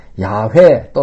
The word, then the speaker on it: 야훼: 그것이 곧 우리말로 여호와라는 발음이 되었는데 아마도 예호와가 그들이 붙이는 모음으로는 더 가까운 발음인지도 모르겠습니다. 예. 그런데 요즘은 그 원래 발음을 찾았다고 해서 학자들이요, 연구를 거듭해서 찾았다고 해가지고, 야훼 또는 야훼라고 발음을 합니다.